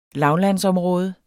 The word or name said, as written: lavlandsområde